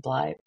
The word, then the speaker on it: Bly: Um, if you liked um, Haunting of Hill House and the Haunting of Bly Manor or Bly Bly, what is it called? Am I saying that right? I think so. The I think Bly you're Manor? right.